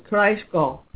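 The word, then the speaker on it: Breisgau: It's pronounced Kreisgau in Baden, and I've got some information on that I'll get to in a minute.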